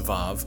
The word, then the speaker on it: Vav: If you look in your Bible, it probably says like Waw, like W-A-W, but in Hebrew it's pronounced Vav.